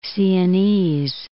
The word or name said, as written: Siennese